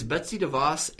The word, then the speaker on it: Betsy DeVos: But I wanted to ask you, Kevin, who is Betsy DeVos, and am I pronouncing that correctly? – Well, so far, so good.